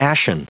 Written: ashen

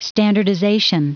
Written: standardization

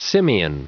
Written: simian

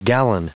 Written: gallon

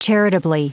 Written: charitably

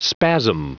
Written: spasm

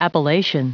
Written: appellation

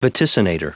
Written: vaticinator

